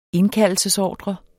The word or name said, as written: indkaldelsesordre